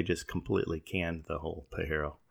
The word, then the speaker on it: Pajero: Mitsubishi has, I believe it would be pronounced Pajero, P-A-J-E-R-O, which means jerk in Spanish.